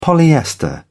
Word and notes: polyester: British English

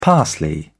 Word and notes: parsley: British English

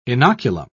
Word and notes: inoculum: British English

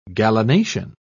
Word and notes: gallinacean: British English

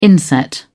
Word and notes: inset: British English

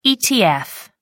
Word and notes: etf: American English

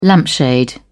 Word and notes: lampshade: British English

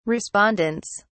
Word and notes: respondents: British English

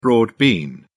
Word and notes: broad bean: British English

